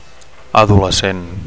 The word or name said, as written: adolescent